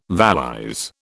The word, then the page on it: valise: British English